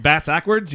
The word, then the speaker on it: bass-ackwards: What, bass-ackwards? You... – Is that how it's said? – Yeah. – Oh, I've never heard that before. – Like ass backwards, it's bass backwards.